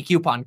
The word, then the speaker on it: coupon: I got flagged in the comment section on another video that I say coupon with a Q. I never knew I was saying it wrong. So thank you.